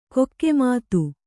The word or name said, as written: ಕಾಕುಮತಿ